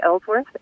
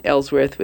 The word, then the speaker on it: Ellsworth: Jeri Ellsworth. It's a really original YouTube name. And that's, my name is spelled Jeri, J-E-R-I. So that's probably the trickiest bit of it. – And then Ellsworth with two L's, and it should come right up.